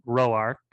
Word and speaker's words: Rourke: So then moving to our second to last, we got Ellen Rourke, or as everyone in the movie pronounces it, Roark, because they're in the South.